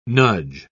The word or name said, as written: nudge